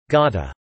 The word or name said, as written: gotta